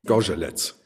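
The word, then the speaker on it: Zgorzelec: Wie spricht man das aus? Ich habe es ein paar Mal mit Herrn Bartusch geübt. – Gorzelec. – Skorzelec. Ich hoffe, das war jetzt richtig so.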